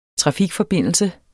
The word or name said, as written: trafikforbindelse